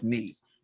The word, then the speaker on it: Nice: Something to dream about, That my dream was to go to Nice, it's pronounced Nice, but Nice, France.